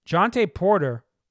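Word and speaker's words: Jontay Porter: Jonte Porter, did I say his name correctly? Yes, I did.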